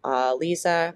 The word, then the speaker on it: Liese: And Liese, I think is how we're supposed to pronounce her name.